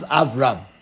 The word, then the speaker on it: Avram: So if you combine the two words Av Aram, it becomes Avram, because the Aleph is a silent letter.